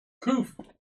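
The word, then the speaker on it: Qoph: Koof. Okay, here we go. – Koof. How do you say that? – Yeah, you got it.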